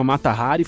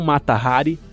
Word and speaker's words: Mata Hari: que eles pronunciam não Mata Hari, mas Mata Hari.